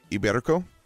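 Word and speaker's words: Ibérico: This is the Spanish, and I might butcher this, Iberico ham. – Okay. You nailed it, actually.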